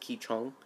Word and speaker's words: Qigong: Now, you don't get it from the pronunciation of Qigong, or it's also pronounced Qichong, but it is spelled Qigong.